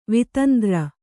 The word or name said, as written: ವಿತಂದ್ರ